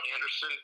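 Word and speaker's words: Andersen: It's at the John Anderson. Keep in mind, my name is spelled most truly, so it's J-O-N, and Anderson is A-N-D-E-R-S.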